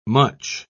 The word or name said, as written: much